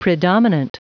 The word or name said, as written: predominant